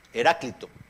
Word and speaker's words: Heráclito: Heráclito, era porque lo tiene el acento en la Heráclito.